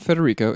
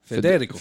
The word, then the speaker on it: Federico: It's not Federico. It's the emphasis is on the second E. – Federico.